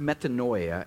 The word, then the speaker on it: metanoia: It's pronounced metanoia, and it means to change.